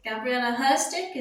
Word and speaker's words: Gabriela Herstik: Gabriela Herstik, is that how you say it? – Yes, ma'am.